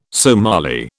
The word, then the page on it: Somali: British English